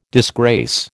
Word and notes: disgrace: American English

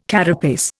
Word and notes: carapace: Indian

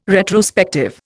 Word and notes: retrospective: Indian

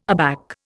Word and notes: aback: Indian

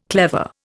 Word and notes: clever: Indian

